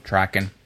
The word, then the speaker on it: Traken: Trocken, right? I think we must have mispronounced it or something. Last time, what did I say? Traken? – I think that's right. Yep. – Traken.